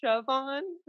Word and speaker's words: Siobhán: Did I say that right? – It's Siobhán.